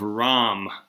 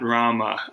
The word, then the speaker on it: Ram: Incidentally, incidentally, it's the epic of Ram, R A M, not Rama. we're using the we're u- using the Hindi uh, the Hindi pronunciation.